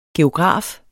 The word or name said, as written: geograf